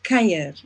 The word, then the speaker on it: kuier: A cure? – Yeah, it's actually pronounced Kair.